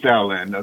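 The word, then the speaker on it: Taoland: I guess it's spelled Dowland. – Uh, it's pronounced Dowland, uh, Taoism.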